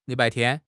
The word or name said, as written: Li3 bai4 tian1